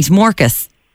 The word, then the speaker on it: morkas: Am I saying it right? Morkas. – Morkas, yeah. That's it, yeah.